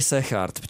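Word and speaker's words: Segaert: kterou vyhrál Alex Sargent, nebo jak se vyslovuje? – Říkali Sechard, čověče, Sechard, dneska jsem jako, to, nikdy jako jsem to vlámsky, neříkal, jo. Ale prej to G se čte jako H, tak já jim budu věřit a PCS to tak taky má pod tím, pod tím amplionkem, tak Sechard.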